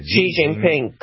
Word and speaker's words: Xi Jinping: Xi Jinping, correct.